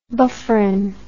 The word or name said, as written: bufferin